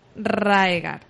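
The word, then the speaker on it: Rhaegar: Maynard pone que eh, anuló el eh, matrimonio del príncipe Rager en Dorn. ¿Qué está diciendo aquí? Gilly pronuncia mal el nombre Rager. – Hmm. En realidad está hablando del príncipe Raegar.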